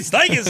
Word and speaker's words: Steichen: Oh, now, don't say it like that. – So like, Shane Steichen. – Go ahead. – Talking about his boss. – Shane Steichen said it's got to be credible.